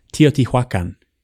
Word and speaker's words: Teotihuacan: There's also this other great example of, I'll get this right, Teotihuacan, which is...